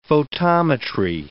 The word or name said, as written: photometry